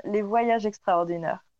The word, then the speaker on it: Les Voyages Extraordinaires: And my guest is paranormal investigator, French TV personality, and CEO of the paranormal tourism company, Les Voyages Extraordinaires. And you can say it. You can say it properly, since I'm not. – No, but I love the way you say it. Les Voyages Extraordinaires.